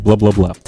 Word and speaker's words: бла-бла-бла: Вам вообще понятна вся эта истерия по поводу «сделан из одного куска», «бля-бля-бля» и прочее, и прочее? – Так, Жень, бля-бля-бля это в русском языке что-то очень матерное. Правильно говорить бла-бла-бла.